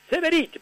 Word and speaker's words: Severiche: Severiche, pero se pronuncia así, se escribe Severiche, sí.